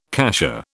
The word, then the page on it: cassia: British English